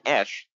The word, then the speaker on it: Esch: But he dropped out of his fight against Eric Butterbean Esch. That's how you pronounce it.